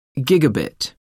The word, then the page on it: gigabit: American English